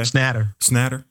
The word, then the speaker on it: Schnatter: Snyder? Is that how you say it? Mm-hmm. Okay. – Schnatter.